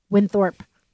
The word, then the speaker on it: Winthorpe: So when Penelope goes to pick up Winthorpe.